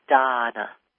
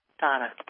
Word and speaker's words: Dahna: You're listening to Akashic Wisdom on BBS Radio, and I'm here listening, and my name is Donna Fox. And I spell my name differently. I spell it D-A-H-N-A. And it's pronounced Donna. The D-A-H is Da, Donna.